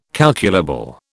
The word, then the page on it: calculable: British English